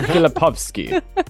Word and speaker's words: Filipowski: It's not Filipowski, huh? It's Filipowski. – Well, actually, you know what? You got it right twice, because you got it correct in the English pronunciation and in the Polish pronunciation.